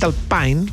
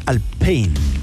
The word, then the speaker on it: Alpine: pero lo dicen mal porque la pronunciación real es más Alpine. No como Alpine. – Claro. No es Alpine. No es anglo.